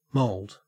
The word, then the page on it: mould: Australian English